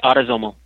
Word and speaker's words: autosomal: And I know in your new article, you're talking about, it's autosomal, am I saying that correctly? – That's right, autosomal.